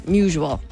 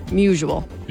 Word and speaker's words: Musial: Musial. – Like usual. – Usual, not musical. Musial.